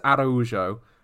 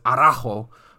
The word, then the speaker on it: Araujo: It is Araujo. And we've been pronouncing her as Araujo, which is very much not correct.